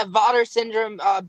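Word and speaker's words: VATER syndrome: is it's Vader syndrome. Am I saying that right? – Yeah. Vader syndrome.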